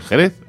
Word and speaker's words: Xerez: El equipo de fútbol de que le llamamos Xerez, realmente se escribe Xerez porque es J. Él se dice Jerez, aunque pone escrito el equipo con X.